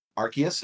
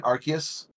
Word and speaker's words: Arceus: I did want to play Arceus. Is that how you say it, Arceus? – No one knows, dude. It's Arceus. There's such a despite online between Arceus and Arceus, and I always say Arceus.